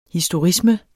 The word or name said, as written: historisme